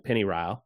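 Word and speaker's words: Pennyrile: There's a region of Kentucky called the Pennyryle, which is a transliteration of the Kentucky pronunciation of Pennyroyal. So they took Pennyroyal, and then Kentuckians pronounce it with their accent, Pennyryle, and they spelled that Pennyryle.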